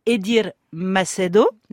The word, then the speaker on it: Edir Macedo: Ils appartiennent à un homme qui s'appelle Edir Macedo, c'est comme ça qu'on prononce, oui, c'est ça.